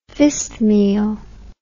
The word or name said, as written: fistmele